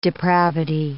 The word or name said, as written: depravity